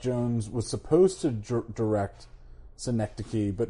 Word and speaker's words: Synecdoche: Synecdoche, New York. – Yes, Synecdoche, New York.